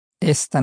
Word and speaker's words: esta: Esta noche. – Esta noche. – And this is pronounced quite close to the English name Esther.